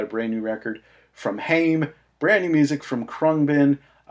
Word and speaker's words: Haim: the third long player from the sisters Haim. You may pronounce that as Haim, H-A-I-M.